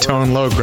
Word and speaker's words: Tone Loc: I had pronunciation lessons during the break. Right. Made sure I got Tone Loc right.